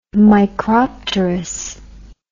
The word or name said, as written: micropterous